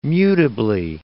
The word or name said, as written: mutably